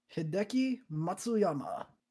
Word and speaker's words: Hideki Matsuyama: Hideki Matsuyama.